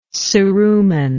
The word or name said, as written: ceruminal